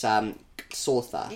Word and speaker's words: Xhosa: I feel like there's a click in there. – Yeah. I screwed up the click badly. It's Xhosa.